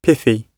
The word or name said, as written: pithy